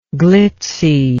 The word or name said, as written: glitzily